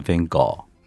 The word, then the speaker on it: Van Gogh: 嗯、后来我发现他的荷兰文的发音是 Van Gogh，梵高，梵高。